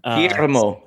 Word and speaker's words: Guillermo: Guillermo is how you say it, and he's the new Spanish starting center over there now that the Gasol brothers are gone.